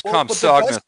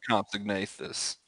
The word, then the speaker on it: Compsognathus: It's Compsognathus, not Compsognathus.